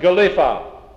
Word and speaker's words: geliffa: It comes from a Anglo-Saxon word, G-E-L-I-F-F-A. You pronounce it Galifa.